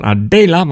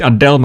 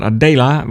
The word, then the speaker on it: Adela: We gaan naar 2026 en dan gaan we naar Adela, want zo moet je het volgens mij uitspreken. Dus niet Adele, maar Adela.